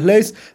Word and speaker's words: Leys: Mi nombre es Lucas Leis.